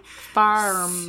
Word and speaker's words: Farmacy: They spell it F-A-R-M-A-C-Y. – Pharmacy.